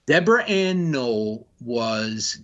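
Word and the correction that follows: Woll: Did I say her name? Vol. – Vol. – Vol, yes.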